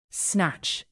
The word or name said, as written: snatch